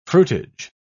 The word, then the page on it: fruitage: British English